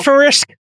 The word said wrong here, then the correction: Symfonisk: How do you pronounce this stuff? – Symphonisk. – S-Y-M-F-O-N-I-S-K. – Symphonisk.